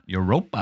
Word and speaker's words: Europa: Europa.